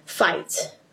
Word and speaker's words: fight: 有时候很多 g h 是不发音，比如说像呃 light。或者是 fight，呃，night，然后你就发现这 i g h t 呢，i g h 那个 g h 里面都没有发音，所以你就可以这样子去记忆这单词，就会发现，哎，好多字其实都已经背过它的一部分的东西了。